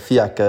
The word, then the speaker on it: Fiaker: Fiaker, ja.